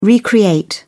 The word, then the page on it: recreate: British English